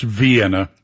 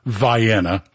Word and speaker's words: Vienna: Okay, I know it's Vienna, but in Georgia we have Houston County instead of Houston. Cairo instead of Cairo. So we'll go with the local pronunciation of Vienna.